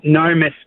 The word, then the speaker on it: Gnomus: So, the pronunciation of my surname is actually Gnomus, but it sounds like Normus. So I get called Ginormous.